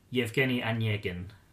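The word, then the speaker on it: Yevgeny Onyegin (Евгений Онегин): Yevgeny Onyegin.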